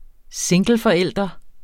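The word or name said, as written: singleforældre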